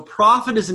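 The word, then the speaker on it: prophetis (προφήτης): So prophet is an English word that is a transliteration of a Greek word through probably French, through Latin, and back into Greek. So prophetes, or o prophetis, modern Greek pronunciation.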